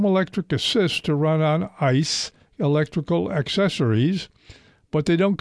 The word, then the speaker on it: ICE: First of all, ICE, I-C-E. Pronounce it that way, you do the word that means frozen water.